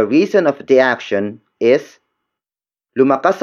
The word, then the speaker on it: lumakas: Word for word, it's lumakas ako ng dahil sa iyo. Lumakas ako ng dahil sa iyo. Lumakas ako ng dahil sa iyo. The first word is lumakas. Lumakas. Lumakas. Lumakas is spelled as L-U-M-A-K-A-S.